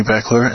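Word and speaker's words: Baechler: Again, my name is Tony Bechler, and thank you for listening.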